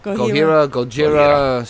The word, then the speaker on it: Gojira: I'm going to play Thor, Gojira, like Godzilla. – Yeah, and we'll be right back. – Is that how you pronounce it? Gojira or Gojira? – Gojira.